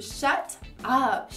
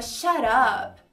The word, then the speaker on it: shut up: Primeira pronúncia fazendo o som do T, claramente, tipo shut up, shut up. Ou você pode transformar o T num R aí no som e fica shut up.